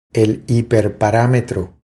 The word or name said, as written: El hiperparametro